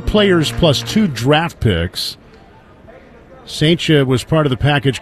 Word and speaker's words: Cijntje: It's spelled C-I-J-N-T-J-E. Here's the throw to first, and Murray dives back. C-I-J-N-T-J-E. So it's got four consecutive consonants, two of which are J's. It's pronounced Saintia, like Saint Cha.